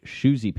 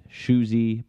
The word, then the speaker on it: Shoesy: which would be Shoesy Pants. So that's at Shoesy Pants.